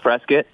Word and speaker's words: Prescott: Pronounced Prescott.